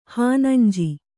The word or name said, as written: ಹಾನಂಜಿ